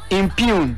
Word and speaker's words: impugn: It is spelled B-O-M-O-M. – Impugn. – Mm-hmm. – Where near come as him? – Yeah. – And near I M P U G N. Impugn. Genu is silent. You don't pronounce it.